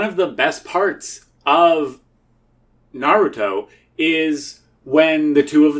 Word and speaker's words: Naruto: It's pronounced Naruto. – Naruto? – When you watch the like subs, it sounds like they're saying Naruto. – Okay. – Like N-A-R-T-O.